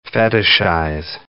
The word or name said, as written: fetishize